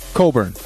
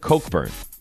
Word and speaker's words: Cockburn: Kofi Coburn. – No. That's not how you say it. – That's how they say it. – Coburn? – How do they pronounce it? – I've heard Cockburn.